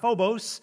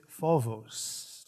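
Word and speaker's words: phobos (φόβος): It is not phobos, it is phobos. Did I get it? I got a nod.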